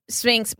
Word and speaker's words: Sphynx: So let's get into Sphynx.